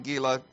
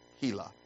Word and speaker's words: Gila: But 3 months after her birth, she moved to our current location on Gila Drive, or Gila Drive, as it is supposed to be pronounced.